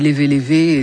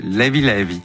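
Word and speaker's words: Leve Leve: DJ Tombé est notre invité pour la sortie du volume 2 de ses compilations sur Sao Tomé et Principe, qui s'appelle, moi je dis Lévé Lévé, et ça très se prononce bien. Pas comme ça. Comment ils disent là-bas? – Lévi Lévi. – Lévi Lévi. Très bien.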